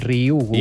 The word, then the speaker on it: Ryugu: És molt fàcil, com una I, Ryugu.